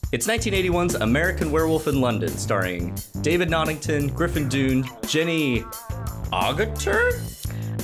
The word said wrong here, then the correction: Naughton: I'm pretty sure you've pronounced every person's name wrong. – I know. David Naughton, Griffin Dunne.